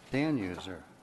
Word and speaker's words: Danhauer: Margaret Danhauer, am I pronouncing your name right? – Dan User. – Phonetically, Dan User.